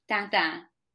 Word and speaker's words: Tintin: Okay, here's the proper way to say Tintin. Dan dan.